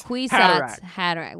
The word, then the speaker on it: Kwisatz Haderach: Kwisatz Haderach. Like cuisance. – Mikolaj. – Haderach. Headache. Cuisant headache.